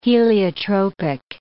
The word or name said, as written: heliotropic